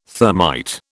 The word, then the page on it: thermite: British English